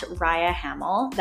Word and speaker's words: Rhia Hamel: My username there is at Raya Hamel. That's spelled R-H-I-A-H-A-M-E-L.